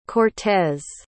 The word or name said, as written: cortez